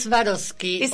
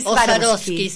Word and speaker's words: Swarovski: Es Swarovski. Swarovski.